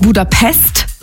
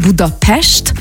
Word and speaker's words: Budapest: Also erstens appreciaten wir das schon mal, wenn man nicht Budapest sagt, sondern Budapest, weil das S immer ausgesprochen wird wie ein Sch.